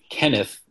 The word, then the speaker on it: Cennydd: Yes, it's pronounced like Kenneth, like K-E-N-N-E-T-H, but it's spelled C-E-N-N-Y-D-D.